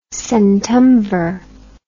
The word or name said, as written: centumvir